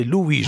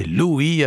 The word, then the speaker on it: Louis: Et donc, j'ai évoqué Saint Louis, bien sûr, auditeur, vous me dites que je prononce très vite le nom, c'est Louis, j'oublie pas cette prononciation, je parle vite dans mes chroniques, beaucoup de choses à dire, donc c'est Louis avec ce O de l'or qui est son âme,